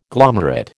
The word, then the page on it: glomerate: American English